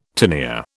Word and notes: tinea: British English

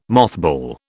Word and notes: mothball: British English

